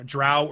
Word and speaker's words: drow: You know, drow or dro.